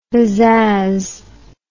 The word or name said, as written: bizzazz